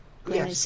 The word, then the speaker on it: Greenwich: Is that Greenwich? Is that how you say it? – Greenwich. Yes.